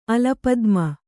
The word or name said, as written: ಅಲಪದ್ಮ